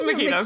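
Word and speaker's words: Mikita: So how were we saying it? – Mitka or something like this. I kind of briefed over it because I knew I was pronouncing it wrong. It's Makita.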